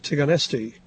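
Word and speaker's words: Țigănești: At least you might think that's how it's pronounced, since it is spelled everywhere T-I-G-A-N-E-S-T-I. However, it seems no one but me has been bothering to re-spell it T-I-G-A-N-E-S-H-T-I, since I know that under the S is a comma or a cedilla, meaning that letter is really pronounced in Romanian like S-H.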